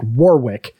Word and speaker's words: Warwick: It's W-A-R-R-I-C-K. And the actor's name is W-A-R-W-I-C-K, like Warwick. So I don't know if, but I think it's kind of pronounced Warwick Davis.